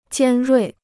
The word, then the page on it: 尖锐: jiān ruì